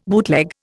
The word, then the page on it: bootleg: Indian